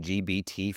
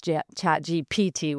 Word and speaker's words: ChatGPT: Okay, I was saying GBT for the longest time. I still say ChatGBT, but I know it's ChatGPT, which is why I asked, but yeah.